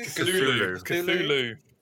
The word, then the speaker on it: Cthulhu: Is it Cthulhu? – Cthulhu. – Cthulhu. – Oh, H.P. Lovecraft is so good. – Yeah, I'm a few stories into a nice old paperback Penguin edition of The Call of Cthulhu and other weird tales or other weird stories or something.